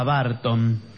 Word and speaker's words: Barton: Nunca vaya a decir que se escribe su apellido con A. Lo busqué por todo Twitter como Burton. – No, Tommy. Es con A, Barton.